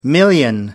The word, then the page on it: million: American English